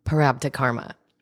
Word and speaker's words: prarabdha karma: How do you say it again? um, parabdha karma. Prarabda karma. Yeah, you got it.